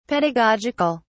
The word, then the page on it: pedagogic(al): British English